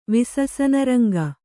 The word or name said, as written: ವಿಸಸನರಂಗ